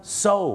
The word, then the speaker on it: sew: ซิวเหรอครับไม่ใช่ซิวครับผมซูโอไม่ใช่นะฮะไม่ใช่ครับซูเนี่ยนะครับภาษาอังกฤษเนี่ยนะครับจะเป็นชื่อผู้หญิงหรือจะแปลว่าการฟ้องร้องคดีก็ได้ครับคำนี้ออกเสียงว่าอะไรไหมครับโซถูกต้องแล้วครับ SEW ออกเสียงว่าโซครับ